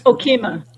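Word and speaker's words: Okemah: And it starts out in, how do you say the name of that town in Oklahoma? Ofeski? Is that it? – Okema. – Okema.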